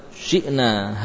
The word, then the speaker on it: شئنا: ولو شئنا لبعثنا في كل قرية نذيرا hati-hati pengucapan huruf-huruf wawu walau ya wawu sukun walau syi'na hamzahnya sempurnakan berikan sifat syiddah syi'na tidak tersisa suara